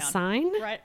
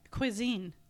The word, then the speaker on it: cuisine: Q-U-I-S-I-N-E. – Q-U-I-S-I-N-E. – How do you say that word? Write it down. – Quassign? – Write it down.